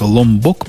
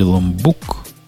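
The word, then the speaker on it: Lombok: Ломбок, по-моему, этот проект. Я проверю, так он пишется. Ломбок. Или ломбук. Да, ломбок, я правильно вспомнил.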